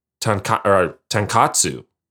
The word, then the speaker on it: tonkotsu: And they pronounce it tonkotsu. And I'm like, oh, what is that? You know, it's like, that's the ramen. But it turns out I'm just pronouncing it bad as an American.